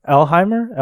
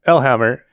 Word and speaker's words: Elhammer: I'm not sure. Is it Elheimer? – Elhammer. I mean, Elhammer. It's literally how it's spelled.